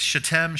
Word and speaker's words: Shittim: The emphasis or the accent's on the second syllable, just so you know. No, it's Shittim, Shittim, right?